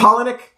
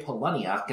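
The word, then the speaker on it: Palahniuk: I think it's Polonick. – Polonick, okay. I was going to say Poloniuk, and I'm like, he might hate me for that.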